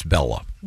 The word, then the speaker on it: Bela: And the question was, is it pronounced Bela or Bella? I've got it from an official member of his family. It's Bella.